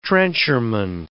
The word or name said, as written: trencherman